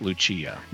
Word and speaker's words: Lucia: It's actually because in Portuguese, the C is pronounced as an S, not a CH. – Yeah, so but in Italian, in Portuguese, it's Lucia, not Lucia.